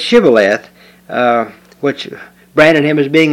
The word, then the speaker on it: Shibboleth: They pronounced SH like it was a plain S. And so when they would ask them to pronounce this word Shibboleth, they would pronounce it Sibboleth.